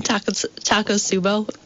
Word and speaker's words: Takotsubo: And what is it called again? I pronounce it wrong. – Takotsubo. – Takotsubo, yeah. Which is Japanese.